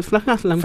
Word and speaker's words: Frank Haslam: Eh, Frank Haslam. – Frank Haslam. ¿Cómo se dice Frank Haslam? – Así, Así mismo, ¿no? en inglés.